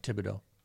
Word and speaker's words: Thibodeaux: And then on the flip side, is it Thibodeaux? I don't know how you pronounce his last name. – Thibodeaux.